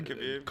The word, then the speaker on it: Khabib: Yeah, Khabib.